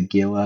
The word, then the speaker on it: Gila: And to the listeners, if you are going to Google Gila, it's not spelt with a H. It's spelt with a G. – That's true. – So be careful. What is it? G-A-I-A or something like that? – No, it's G-I-L-A, Gila. – G-I-L-A, it's spelled like Gila.